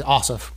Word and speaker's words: Ossoff: So when the other candidates, it's Loeffler and Warnock and Perdue and Osnoff. – I think Ossoff, but yeah. – Ossoff, that's right. I'm sitting here looking at it and said it wrong.